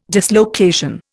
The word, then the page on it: dislocation: Indian